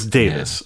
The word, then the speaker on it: Davies: It's Davis. Yeah. Okay, because it's spelled... In America, we might call that a Davies. – We might do in parts of the UK as well, but it's the Welsh iteration of Davies, and it is traditionally pronounced Davis.